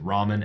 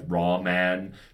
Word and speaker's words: Raman: I guess I suppose I'm always not quite pronouncing that correctly, but, like, Raman spectroscopy is spelled R-A-M-A-N. So not like Japanese noodle spectroscopy.